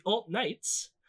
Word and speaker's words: Alt Knights: In March 2017, Gavin allowed a convicted felon named Kyle Chapman to join the Proud Boys to lead a group called the Alt Knights, rhyming with alt-right.